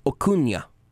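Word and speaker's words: Acuña: It's a hard C there. – Acuna. – Acuna. – Made it Osuna, and Rudy forgot the Enye. Acuna. It's like jalapeno.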